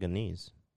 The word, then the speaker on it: Anees: And I guess Anis. It's probably what's the name? Can you spell that shit out for me, please? – A-N-E-E-S. – Sounds like a niece, or a niece.